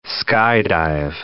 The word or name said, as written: skydive